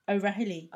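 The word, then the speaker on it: O'Rahilly: So Radio Caroline launched on Easter Saturday on the 28th of March in 1964 by Ronan O'Harely. How do you say that? You're Irish. – O'Harely. – Raheely. – Raheely. – O'Raheely. – O'Raheely. – That'll do. O'Harely.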